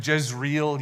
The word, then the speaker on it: Jezreel: And I'm going to use the Americanized pronunciation of his name, Jezreel. You've heard that before. I think the actual Hebrew is something like Yezreel or something like that.